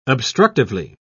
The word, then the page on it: obstructively: British English